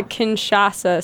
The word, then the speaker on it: Kinshasa: is it Kashina Symphony Orchestra in Central Africa? – It's the Kinshasa Symphony Orchestra, and that Kinshasa is the capital city of the Congo, of the DR Congo.